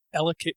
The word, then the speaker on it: Ellicott: Ellicott is how it's pronounced.